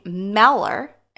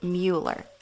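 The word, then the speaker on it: Müller: He pronounced his name Meller, and most people today pronounce it Mueller.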